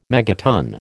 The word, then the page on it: megaton: American English